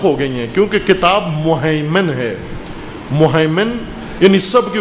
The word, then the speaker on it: مہیمن: لما بین یدیہ من الکتاب و مہیمناً علی، اور قرآن کا ہیمنا ہے، مہیمن ہے، مہیمن، تلفظ ہو جائے گا انشاءاللہ. مہیمن، مہیمن، مہیمن کہتے ہیں جس کا ہیمنہ ہو، تسلط ہو، سایہ ہو.